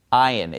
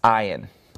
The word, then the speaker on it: Ayin: Ayin. It's pronounced just like the Zion. Remember the letter Zion? Okay? Except it's without the Z. It's Ayin.